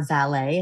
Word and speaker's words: valet: Charlie is the valet. That's how you pronounce that. I did not know it. It's not like a car valet. It's a valet.